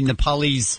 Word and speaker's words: Nepalese: Here's some of the tape, and then I'll give you some translation of what the Nepalese, is that how you say it? – Yes.